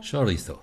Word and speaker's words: Chorizo: Chorizo, habe ich das richtig ausgesprochen? – Wow. – Muy bien. – Muy bien, ja.